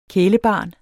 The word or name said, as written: kælebarn